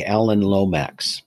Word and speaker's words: Alan Lomax: So you had mentioned on a couple of your, the writings on the different websites, you had mentioned people like Alan Lomax as an influence.